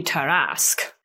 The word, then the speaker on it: Tarrasque: Say it again? – Tarrasque. – The Tarrasque? – How do you spell that? – Okay. A note on that in a minute. – Oh. – But what is in front of me right now is Tarrasque. It's French.